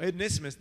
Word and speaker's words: Onesimus: So it's pronounced Onesimus. So Onesimus, this slave, this servant, the entire plot of this is that he robbed Philemon and he ran away to Rome.